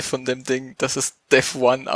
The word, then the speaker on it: Devuan: Und so gibt es auch ein paar Leute, die Debian gerne ohne Systemd hätten und haben jetzt da quasi neues Debian Devuyan. – Es wird Devuan ausgesprochen, habe ich vorher gerade gelesen.